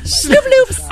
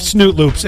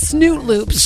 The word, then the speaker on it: Snoop Loops: All right, I'll finish off Snoop Loops. It's like Fruit Loops with marshmallows. – You can't even say this right. You just said Snoot Loops.